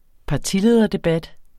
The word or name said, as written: partilederdebat